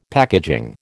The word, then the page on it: packaging: American English